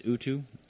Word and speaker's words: Utu: It's basically because I'm working on some protocols and some stuff that's on the evil side, And one of them is Utu. It's U-T-U is how it's spelled.